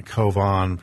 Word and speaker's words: Calvin: So a little bit later, not too much later, but in 1536, a man named John Calvin, and that's not how he actually pronounced or spelled his name. He was a French theologian. John Covan probably was the way he pronounced his name, authored the Institutes of the Christian Religion.